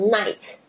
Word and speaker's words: night: 像 night。 N-I-G-H-T 它的 I-G-H 基本上都没有发音，所以你会看到很多的字，比如说像 light 灯光 L-I-G-H-T 那个 I-G-H 也是没有发音，